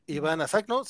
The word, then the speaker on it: Ivanna Sakhno: Ivana Sacno, ¿no? – Justamente se trata de Ivana Sacno, se suma al elenco de Ahsoka, aunque aún no revelan qué personaje.